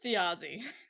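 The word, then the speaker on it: Þjazi: Okay, it's a thorn. J-A-Z-I. – There we go. Pronounced Thiazi.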